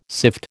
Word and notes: sift: American English

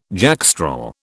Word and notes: jackstraw: British English

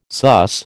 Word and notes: sus: American English

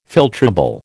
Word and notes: filtrable: American English

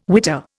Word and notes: witter: Indian

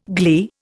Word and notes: gley: Indian

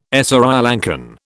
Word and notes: Sri Lankan: British English

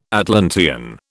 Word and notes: Atlantean: British English

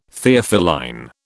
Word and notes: Theophylline: British English

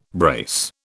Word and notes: brace: British English

